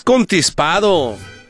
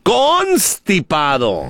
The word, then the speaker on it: constipado: No es contispado, es constipado.